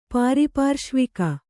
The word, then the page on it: ಪಾರಿಪಾರ್ಶ್ವಕ: pāripārśvaka